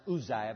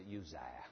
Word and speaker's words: Uzziah: Probably pronounced Uzziah, but we as Anglos, we pronounce it Uzziah.